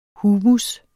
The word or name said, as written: humus